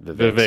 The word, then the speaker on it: Vivek: Vivek. Sorry. If we're going to do 20 minutes on Vivek, you better be pronouncing his name right.